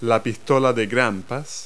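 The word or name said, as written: staple gun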